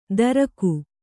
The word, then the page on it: ದರಕು: kannada